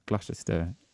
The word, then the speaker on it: Gloucester: Am I saying that right? Gloucester? – Gloucester. – Okay. O-U-C-E-S-T-E-R. G-L-O-U-C-E-S-T-E-R. – Yeah, you never pronounce it the way that it's...